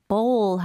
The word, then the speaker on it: bowl: 지금 들으니까 공 할 때 B-A-L-L과는 발음이 다른 것 같습니다. – 네, 완전히 달라요. 공 같은 경우는 그냥 턱이 내려가는 ball. – Ball. – Bowl 하면 입술이 동그래지는 느낌이 나야 돼요.